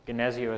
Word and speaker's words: Gnesio: Gnesio is spelled G-N-E-S-I-O, Gnesio Lutherans.